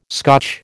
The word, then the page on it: Scotch: American English